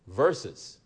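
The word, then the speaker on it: Verzuz: And we went into a program that started last year, March 2020, entitled Verses. But it's spelled V-E-R-Z or Z-U-Z, verses,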